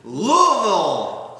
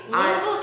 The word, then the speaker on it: Louisville: Hey, Owl Pellets, welcome to Louisville! – Look at you! Look at you actually saying it! We've only been doing this for hours. Look at you saying it right. Louisville, Kentucky.